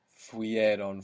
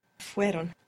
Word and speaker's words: fueron: Now, to make they or you plural was or went, we would add on ieron, but we will find it very difficult to pronounce fuieron, fuieron. So the e sound, the I, fell away. So how would that sound? – Fueron. – Fueron. So the I, the E sound, falls away.